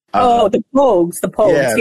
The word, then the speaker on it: The Pogues: And I'm going to screw it up, so I'll just spell it P-O-G-U-E-S. – The Pogues.